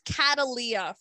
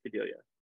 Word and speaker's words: Cadelia: She asks, how do you pronounce Cadelia? or Cadelia. That's how I okay. – She yeah. She thinks it's Catalia for some reason. – I mean, sure, you know, it's translated from an alien language anyway, so why not? But I pronounce it Cadelia.